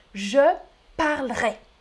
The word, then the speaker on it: parlerai: Ok Vous voyez, avec les verbes, euh, avec l'infinitif en ER, comme parler, euh, manger, se doucher, on on supprime le E de la prononciation. Le E disparaît, ok Je parlerai. On coupe le E, je parlerai.